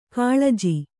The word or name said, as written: ಕುಲಜ